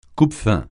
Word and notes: coupe-faim: French

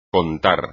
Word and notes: contar: espagnol

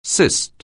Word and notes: cyst: anglais

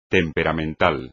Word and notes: temperamental: espagnol

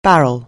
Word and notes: barrel: anglais

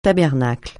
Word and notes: tabernacle: francais

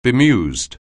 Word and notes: bemused: anglais

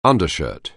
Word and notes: undershirt: anglais

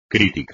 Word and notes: crítico: espagnol

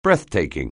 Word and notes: breathtaking: anglais

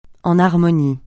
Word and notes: en harmonie: français

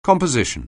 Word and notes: composition: anglais

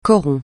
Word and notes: coron: francais

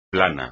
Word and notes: plano: espagnol

